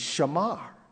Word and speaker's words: shamar: Keep is such a seemingly innocent word, but keep in the Hebrew is shamar. We would English spell it S-H-A-M-A-R, shamar.